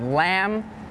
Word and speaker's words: lamb: And some examples with a silent B are lamb, thumb, and doubt.